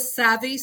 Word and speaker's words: Savvy: So that's how I came up with Savvy Soap. – That's funny. Now, you spell it with two. Is it always spelled with two Vs? Is it always S-A-V-V-Y? – Yes. S-A-V-V-Y.